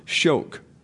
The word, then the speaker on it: Schoeck: The major resource for this study is the book Envy, A Theory of Social Behavior by Helmut Schoch, reprinted in English in 1987 by Liberty Fund Incorporated from the original 1966 German edition. The surname here is S-C-H-O-E-C-K. It is pronounced Schoch.